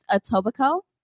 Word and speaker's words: Etobicoke: It's pronounced Etobicoke, and it is between Mississauga and Toronto.